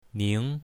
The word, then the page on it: ning2: mandarin